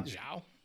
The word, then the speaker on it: Zhao: am sorry if i'm mispronouncing her name yeah i think you're um, right zhao but her her thing is that she likes to use only natural light in her movies.